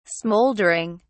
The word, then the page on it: smouldering: British English